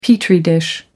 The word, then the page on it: petri dish: American English